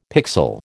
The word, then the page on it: pixel: American English